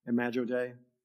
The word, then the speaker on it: Imago Dei: So we're talking about the, the imagio Dei. If I said that right, is that how you say it? Imago Dei.